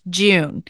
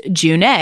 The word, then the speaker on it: June: So the first thing, everyone, is that this elven god is not pronounced June, even though it's spelled that way. It's actually pronounced June, which, thank you, Caitie, for your YouTube video, because I would not have known.